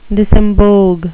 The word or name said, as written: disembogue